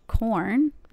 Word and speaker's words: Quorn: All right, we're gonna move on to corn. That's how you pronounce it, right? – Corn. Corn. – Q-U-O-R-N.